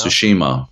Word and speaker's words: Tsushima: Ghost of Tsushima. Is that how you pronounce Tsushima.